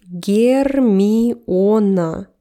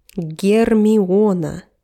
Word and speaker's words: Гермиона: So you hear how we pronounce her name in Russian. – Гермиона. Гермиона. Гермиона Грейнджер, she is in Russia.